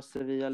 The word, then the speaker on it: Sevilla: Kan du uttala Sevilla lite rätt? – Sevilla.